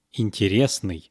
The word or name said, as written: interesnyy